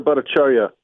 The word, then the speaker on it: Bhattacharya: Dr. Bhattacharya, am I saying it right? – Yes.